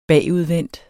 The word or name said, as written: bagudvendt